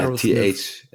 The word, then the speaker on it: Aerosmith: Genau, ja, Aerosmith. TH. TH ist echt schwierig.